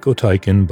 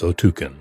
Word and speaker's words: Otyken: And if you want to look them up yourself, it's spelled O-T-Y-K-E-N. It looks like Otykin, but it's Otukin.